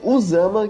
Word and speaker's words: Usama: Um, inventando esse San Martín, e outro, é assim, ele chega até a falar Al-Qaeda, mas ele não bota o nome Osama. Ele escreve Usama, que é como se pronuncia, exatamente, né, nos Estados Unidos.